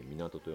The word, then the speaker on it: 水門: この港っていうのは水門と書いて港と読むんだそうですけども、